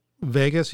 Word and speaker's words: Vegas: Vegas, yes. It's like name of the town.